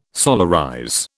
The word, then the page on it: solarize: British English